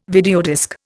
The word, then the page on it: videodisc: Indian